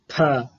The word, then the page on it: ph: Sanskrit